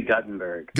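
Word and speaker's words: Guttenberg: And you are a pastor of a dual parish, tell me if I've got the pronunciation correct, Trinity in, do you say Gutenberg or Gutenberg? – We say Gutenberg.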